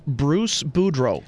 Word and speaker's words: Boudreau: Bruce Boudreau.